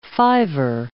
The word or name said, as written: fiver